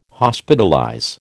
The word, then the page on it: Hospitalize: American English